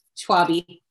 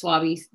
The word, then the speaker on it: Schwabe: It's really common, but if the way I say it is like wasabi, Schwabe. But my dad, he says Schwabe. I mean, I guess it still rhymes with wasabi, whatever.